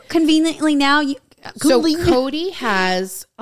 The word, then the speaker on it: Kodye: So her Instagram is uh, Kodye, but it's spelled K O D Y E. Okay. So it's Kodye Elise.